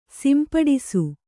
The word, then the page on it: ಸಂಪುಟಿಸು: sampuṭisu